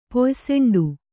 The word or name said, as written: ಪೊಯ್ಸೆಂಡು